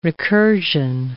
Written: recursion

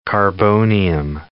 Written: carbonium

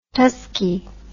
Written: tuskee

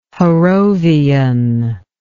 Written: Harrovian

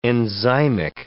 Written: enzymic

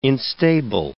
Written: instable

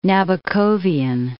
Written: Nabokovian

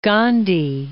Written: Gandhi

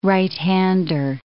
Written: right-hander

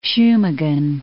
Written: Shumagin

